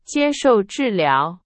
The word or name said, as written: 治疗